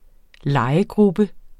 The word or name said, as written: legegruppe